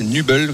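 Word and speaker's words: Nübel: Nubel d'ailleurs et pas Nubel, c'est Nubel. Voilà comment ça, se prononce.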